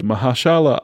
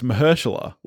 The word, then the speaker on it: Mahershala: And it stars Shameik Moore, Jake Johnson, Hailee Steinfeld, Mahershala Ali, Brian Tyree Henry, Lily Tomlin, Luna Lauren Velez, John Mulaney, Kimiko Glenn, Nicolas Cage, and Liev Schreiber.